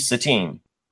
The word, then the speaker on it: Satine: named Satine, which rhymes with Sabine, if you're not confused by now.